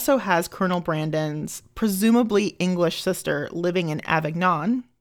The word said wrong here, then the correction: Avignon: I probably butchered that. – Avignon. – Sorry. It's Avignon. French.